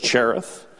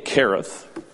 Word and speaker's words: Cherith: It's Cherith. Some people pronounce it with the hard K, Cherith.